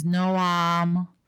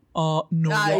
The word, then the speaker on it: Noam: Americans are like, Norm? Noam.